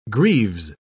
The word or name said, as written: greaves